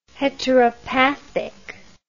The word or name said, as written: heteropathic